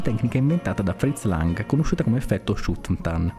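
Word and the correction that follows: Schüfftan: scusate, Schuftan, la prima pronuncia non è,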